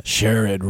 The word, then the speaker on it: Sherrod: Sherrod rhymes with Herod.